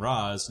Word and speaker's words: Ra's: But they talked about how they had to justify it because in the comic books they pronounce it Raish, but then all those Christopher Nolan movies kept saying Raz.